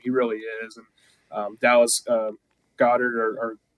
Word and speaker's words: Goedert: It's Goedert. – But Goedert, okay. – I always tell people it's like the Jimmy Neutron dog. – Yeah, okay, there you go, Goedert.